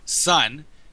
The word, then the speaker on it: Sonn: He says that it's pronounced Sun, but spelled with two N's. So S-O-N-N, which I also can't pronounce. So Sun.